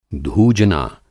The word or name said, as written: धूजना